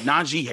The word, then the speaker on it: Najee: But Najee Harris, which he confirmed on the Adam Schefter podcast this week, that, you know, that's how you pronounce his name.